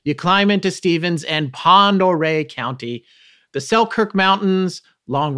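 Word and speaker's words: Pend Oreille: Up past Spokane, where you climb into Stevens and Pendore County. Did I say that right? – Pondere.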